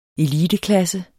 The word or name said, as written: eliteklasse